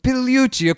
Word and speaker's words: Pigliucci: The G is silent, so it's Pigliucci. – Pigliucci, okay, I'm sorry, I should have asked that.